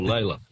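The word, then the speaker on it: Lalor: Oh, Lawler. Or Layla. Layla. Yeah. Lawler's how it's supposed to be pronounced, but Layla's the other way.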